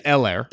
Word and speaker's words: Helaire: I do think, like, watching the Chiefs the last couple weeks, that remember the first game against the Texans when Clyde Edward Helaire. H is silent? – Yeah. Helaire.